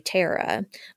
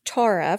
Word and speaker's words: Tara: So I don't know if it's the Eastern Kentucky in me, but when I see her name, I want to say Tara, but we've looked it up and it's pronounced Tara from where she's from.